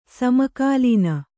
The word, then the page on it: ಸಮಕಾಲೀನ: sama kālīna